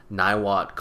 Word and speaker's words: Niwot: And that's where I grew up, in the small town of Niwot, Colorado. That's spelled N-I-W-O-T. – And it's I think two-win backwards. – It is two-win backwards.